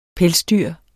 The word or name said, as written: PELSDYRET